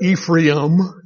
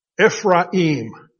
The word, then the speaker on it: Ephraim: We pronounce that Ephraim as if the I is in front of the A. It's not. Ephraim.